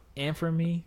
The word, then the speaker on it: Anfernee: Anfernee? – Yeah.